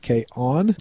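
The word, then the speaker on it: K-On!: K-On, it's actually spelled K with a hyphen, then O-N, then exclamation point. I pronounce it K-On. That's how I do it.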